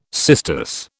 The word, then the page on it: cistus: British English